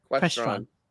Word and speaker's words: Crestron: Crestron.